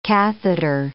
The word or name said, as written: catheter